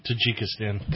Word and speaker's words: Tajikistan: how do you say that? Tajikistan? – Tajikistan. – Thank you. There you go.